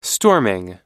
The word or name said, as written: storming